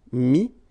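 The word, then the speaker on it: mis: I-R-E-I-T, same group. Dire, dit. Okay, then E-T-T-R-E will give you this I-S, but then you don't pronounce the S, so you get I. Mettre, mi. And then E-N-D-R-E, I-S, but then same thing here, the sound I.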